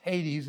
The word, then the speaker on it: Hades: or Hades, generally pronounced Hades in the New Testament.